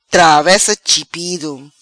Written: Travessa chipido

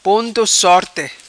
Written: Ponto sorte

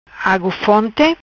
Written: Agu fonti

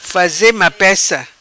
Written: Faze mapeca

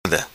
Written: Subi Escada

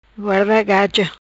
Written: Guarda Gaja

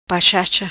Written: pachacha

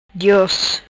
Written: Dios

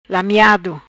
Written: Lameado

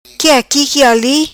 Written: qui aqui qui ali

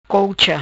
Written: Colcha

